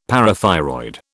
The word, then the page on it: parathyroid: British English